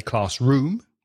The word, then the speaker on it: classroom: Speaking about pronunciation with compound nouns, the stress, la sílaba más fuerte, the stress is usually on the first word. For example, class and room, classroom. Dade, classroom. So we don't say classroom, we say classroom with the stress on the first word.